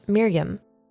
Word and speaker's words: Miriam: Mary in Hebrew is spelled M-R-Y-M, and it is pronounced Miriam.